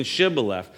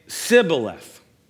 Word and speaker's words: Shibboleth: Because the men of Ephraim could not pronounce the sound of SH, the SH sound in Shibboleth, and they would instead say Sibboleth.